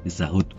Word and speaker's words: Pizza Hut: coba membenarkan itu yang benar bacanya Pizza Hut loh tapi saya tetap ngeyel karena tulisan di sana itu tulisannya P I Z Z A H U T Hut bukan H A